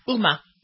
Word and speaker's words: Huma: Is it Huma or Uma? – Uma.